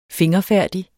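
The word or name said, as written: fingerfærdig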